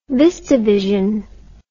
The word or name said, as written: VistaVision